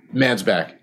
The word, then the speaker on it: Mansbach: Mansbach.